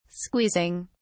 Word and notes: squeezing: British English

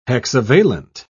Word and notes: hexavalent: British English

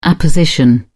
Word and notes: apposition: British English

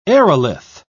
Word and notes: aerolith: British English

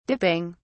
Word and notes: dipping: British English

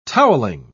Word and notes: toweling: British English

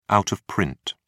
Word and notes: out of print: British English

